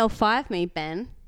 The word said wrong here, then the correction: ELI5: No, you didn't say it right. – Oh, L5? – ELI5.